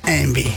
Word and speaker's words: Yngwie: No, Yngwie. – No, si lo buscas escrito es imposible. Y N G W I E. – Ay, imposible. – Por eso. Pues se pronuncia Envy. – Claro, por la Y.